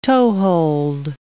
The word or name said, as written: toehold